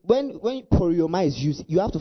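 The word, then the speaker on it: poreuomai: So the word I go here, I'll give you the word, is the word poriomai. Poriomai. Now I'll spell it for you. It's P-O-R-E-U-O-M-A-I. P-O-R-E-U-O-M-A-I.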